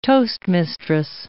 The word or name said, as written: toastmistress